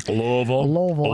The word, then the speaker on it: Louisville: Louisville.